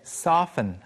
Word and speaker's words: soften: There's a T there, but usually we just say soften.